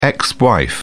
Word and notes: ex-wife: British English

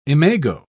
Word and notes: imago: British English

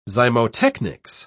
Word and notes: zymotechnics: British English

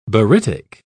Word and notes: barytic: British English

